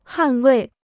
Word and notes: 捍卫: hàn wèi

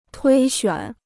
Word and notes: 推选: tuī xuǎn

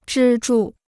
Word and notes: 支柱: zhī zhù

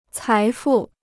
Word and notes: 财富: cái fù

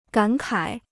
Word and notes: 感慨: gǎn kǎi

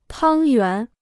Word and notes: 汤圆: tāng yuán

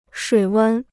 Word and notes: 水温: shuǐ wēn

